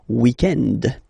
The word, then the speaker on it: weekend: So if we're talking about the weekend, okay, but then pronounce it the French way, weekend.